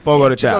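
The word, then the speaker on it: Fogo de Chão: I think it was Fago the Chow. I think I'm pronouncing it right. – Fogo the Chow.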